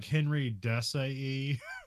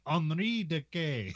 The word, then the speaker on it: Henri Decaë: We got cinematographer is, now it looks like Henry Desailly or something, but you're supposed to say it like Henri Decay. – Henri Decay.